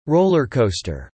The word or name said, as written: roller coaster